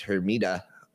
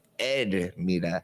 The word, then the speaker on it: Hermida: My last name is Hermida. That's the way the gringos say it. But when, you know, Hispanic people say it, they say Edmida. They don't even pronounce the H.